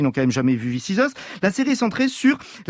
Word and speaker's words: This Is Us: This Is Us, je le dis bien là. – Well done, perfect.